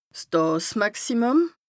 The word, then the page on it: 's Dààsmàximùm: Bas Rhin